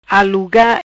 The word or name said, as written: Aluga